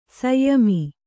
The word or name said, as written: ಶ್ಯಾಮ